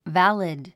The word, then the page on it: valid: vǽlid; バリド